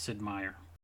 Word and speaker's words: Sid Meier: Is that how you say his name? Because my friends say it differently because they are not familiar with that. – 99% sure it's Sid Meier.